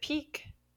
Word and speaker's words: pique: Question number 2, Pique, in English we pronounce it peak, or we might spell it P-E-A-K of mountains.